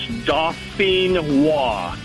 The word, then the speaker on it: Dauphinois: It's, like I said, it's French. It's Dauphinois. – Dauphinois. – And Dauphinois.